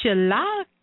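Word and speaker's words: shalak (चालाक): But the actual spelling is C-A-L-A-K-A. So Go ahead. – Go ahead. – I was just going to say, so can you sound it out for us again? C-A-L? – Yeah. Mm-hmm. So the word is spelled C as a cat, A-L-A-K-A. But it's actually pronounced shalak. – Shalak. – So, shalak. It's better to write it as C-H-A-L-A-K so that you remember how to pronounce it.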